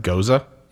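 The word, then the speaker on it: Gose: What is how to spell that word? – G-O-S-E-S. – G-O-S-E-S? It's goza. Pronounced goza? – Yeah.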